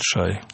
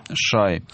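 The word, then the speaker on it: Shea: Ши или Шай. Так, это правильно ведь? – Американцы говорят, произносят Шай. – Шай.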